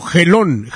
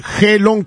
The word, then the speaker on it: Gelón: Gelón. Gelón con G. Gelón. Gelón. Gelón, con acento en la O y la N al último.